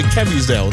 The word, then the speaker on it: Caviezel: Jim Caviezel, I can never say his name, Caviezel, there we go, Jim Caviezel, who was in The Passion of Christ, Person of Interest, one of my favourite shows, he's 58.